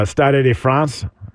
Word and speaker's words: Stade de France: Stade de France, that's how you say it, I don't really speak much French.